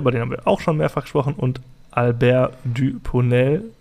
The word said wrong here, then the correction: Dupontel: äh, Dupontel würde ich ihn jetzt mal aussprechen.